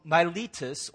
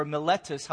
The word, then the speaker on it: Miletus: And so he's in that kind of mindset when the ship that he's riding sets port in a place called Miletus or Miletus, however you want to pronounce it.